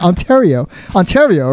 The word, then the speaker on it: Ontario: Why can I not pronounce that? Ontario, right? – Yes, correct.